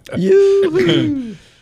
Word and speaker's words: Yoo-hoo: I've been pronouncing them wrong all these years. – As connoisseurs call them Yoo-hoo.